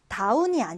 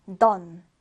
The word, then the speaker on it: dawn: 다운이 아니에요. done, done 이렇게 발음해 주는데 done 하면 새벽, 동이 틀 무렵을 얘기합니다.